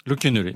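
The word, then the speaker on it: Locquénolé: Euh, dans Ouest France, j'ai appris que le village, et vous allez peut-être me dire si je le prononce pas bien, Loquenolé. Loquenolé.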